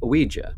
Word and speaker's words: Ouija: In 1901, Fuld started production of his own boards under the name Ouija, or Ouija, or Ouija. I think people just say it however they want. I think most people basically say it like W-E-E-J-E-E, but...